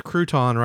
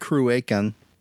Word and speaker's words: Cruachan: That was Crouton right there with the Queen. And then, not Crouton? – I mean, I think they wanted to be pronounced Crouacan.